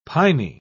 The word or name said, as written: piny